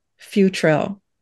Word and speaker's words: Futrell: I'm listed by my name, Lisa Futrell. I think I'm the only Lisa Futrell. And I'm on Facebook. – Spell it. – F as in Frank, U, T as in Tom, R-E-L-L, Futrell.